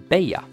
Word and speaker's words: Bella: Dubbel L blir ju som bekant J på spanska och av den anledningen så skulle man uttala namnet Bella.